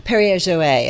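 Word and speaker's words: Perrier-Jouët: Okay, so maybe not quite yet the time to crack open the Perrier Jouet, if I may say. – Oh, really good pronunciation of that.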